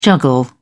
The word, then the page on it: juggle: British English